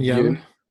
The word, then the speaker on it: Yeun: Stephen Yeun.